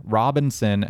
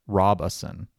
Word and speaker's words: Robeson: and then finally the one that I've been assured is correct, Robeson. – Robeson. Okay. Mm-hmm. – It's spelled like Robeson, but yeah. They said there are no robes in Robeson.